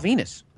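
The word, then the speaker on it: Venis: Eddie Guerrero, another quarter of the Radicals, and former Intercontinental Champion Val Venus, which I think it rhymes with penis.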